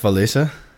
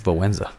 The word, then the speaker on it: Wałęsa: Well, how did you pronounce his name? – Lech Walesa. Isn't that right? – I think it's Wałęsa.